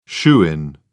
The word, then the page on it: shoo-in: British English